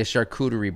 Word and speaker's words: charcuterie: Every meal is basically a charcuterie board. You like lay all right. that shit out? I like it. I pronounced that correctly, right? – Yeah, charcuterie? – You did.